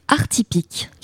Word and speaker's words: Artypique: Donc, collectif Artypique, comme ça se prononce.